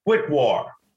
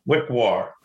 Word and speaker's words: Wickwar: Wickwar. Wickwar. Say W-I-C-K-W-A-R.